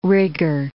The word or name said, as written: rigor